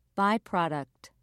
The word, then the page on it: byproduct: British English